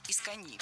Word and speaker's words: искони: Это наречие имеет ударение на последнем слоге и пишется с одной «н» – «искони».